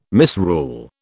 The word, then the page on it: misrule: British English